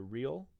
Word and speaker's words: Riehl: Peter Riehl. My last name is spelled R-I-E-H-L.